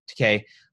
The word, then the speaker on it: Takei: People like George Takei, I don't know how to say his name, Takei, who, you know, "I know what concentration camps are.